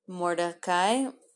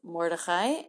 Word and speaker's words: Mordecai: Mordecai, which I might pronounce Mordechai, and Haman.